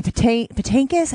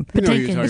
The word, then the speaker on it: Patinkin: And as a kid, again, from that generation, watching The Princess Bride being read by Robin Wright and Manny Patinkin. I never know how to pronounce his last name. Patinka? How do you pronounce his last name? – Patinkin. – It's just easy.